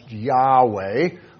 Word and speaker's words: Yahweh: Yod, hey, vau, hey, YHWH, and it's pronounced Yahweh. That is his name.